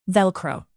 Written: velcro

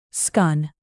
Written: skun

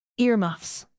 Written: earmuffs

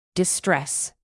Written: distress

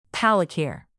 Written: palikir

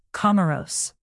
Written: comoros

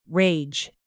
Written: rage

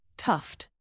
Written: tuft

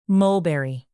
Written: mulberry

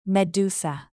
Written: medusa

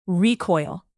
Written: recoil

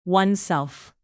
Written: oneself

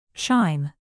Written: chime

